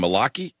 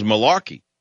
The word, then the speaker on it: Malachi: And this is under, I don't even know how to pronounce it, Malachi, M-A-L-A-C-H-I. I had an Irish friend by that name. I don't even know it was a Jewish name, Malachi. I thought it was Malarkey, but it's Malachi.